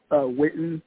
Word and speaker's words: WITIN: You say Witten, or how do you say it? – Yeah, it's Witten, like the football player Jason Witten, but it's spelled.